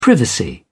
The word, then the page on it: privacy: English; British English